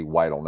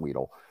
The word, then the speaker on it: Weidl: By the way, I just listened to a video to hear the pronunciations. Andy Weidel, not Weedle.